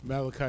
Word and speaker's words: Malachi: Malachi, right? Malachi is the proper pronunciation.